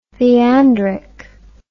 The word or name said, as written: theandric